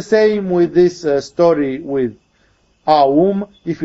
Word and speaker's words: Aum: And then, actually, when you pronounce it colloquially, you can as well say Om. Remember, if you say Om, it is conceptually wrong for your meditation, because you have destroyed the triadic structure.